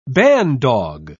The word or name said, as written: bandog